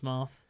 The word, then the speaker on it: Marth: Is that Marth? – It is Marth.